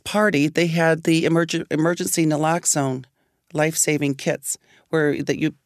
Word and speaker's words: naloxone: What? How do you say it? – Naloxone. It's available at most places now.